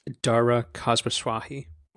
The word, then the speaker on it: Dara Khosrowshahi: Dara Khosrowshahi.